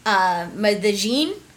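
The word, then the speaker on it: Medellín: Which I actually think is pronounced Medellin in Spanish.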